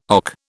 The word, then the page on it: OC: British English